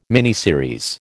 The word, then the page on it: miniseries: American English